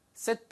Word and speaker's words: sit: Sit back. 되게 간단하죠. 이것도 길게 발음하시면 안 돼요. seat라고 하면은 걔는 S-E-A-T를 이야기합니다. 좌석 혹은 앉히다 라는 뜻이에요. 그래서 얘는 sit, 짧게, sit back.